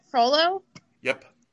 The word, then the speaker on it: Frollo: I think it's going to be Frollo. Wait, did I say that right? Frollo? – Yep.